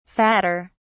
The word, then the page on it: fatter: British English